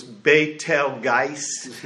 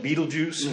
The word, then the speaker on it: Betelgeuse: It's the original pronunciation was Betelgeuse, but after the movie came out, everybody calls it Betelgeuse.